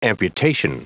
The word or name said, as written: amputation